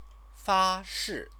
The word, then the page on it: fashi: Chinese